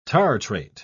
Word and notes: tartrate: British English

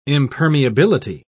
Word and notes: impermeability: British English

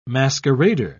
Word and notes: masquerader: British English

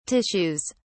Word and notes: tissues: British English

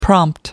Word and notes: prompt: American English

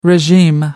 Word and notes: regime: American English

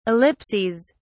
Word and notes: ellipses: British English

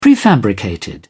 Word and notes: prefabricated: British English

